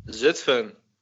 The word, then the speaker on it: Zutphen: Zutphen. – Zutphen. – Zutphen. yeah, yeah, man. – Okay. Why don't you tell people what's like in Zutphen? – Ah, Zutphen is cool, man.